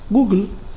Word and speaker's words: Google: Google, esa es la pronunciación.